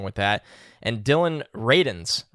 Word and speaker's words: Radunz: I saw somebody said it was like raisins with a D, so Radunz.